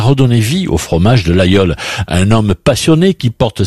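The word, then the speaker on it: Laguiole: Ça s'écrit l'Aguiole, mais on prononce l'Aïole.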